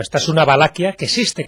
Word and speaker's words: Valaquia: No, es Valaquia, Valaquia. Se puede escribir Valachia, como también se escribe en algunos sitios.